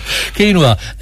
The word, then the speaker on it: quinoa: But if you're taking them, if you look for your chickpeas or your quinoa, what was it? Quinoa? No. Quinoa. That's how you say it. Yeah, quinoa.